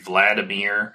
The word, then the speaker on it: Vladimir: One thing I'm curious about, this is sort of just a very tangent question, is the way that you pronounce Vladimir Putin's name, like Vladimir? – Vladimir. Yeah, that's the Russian pronunciation.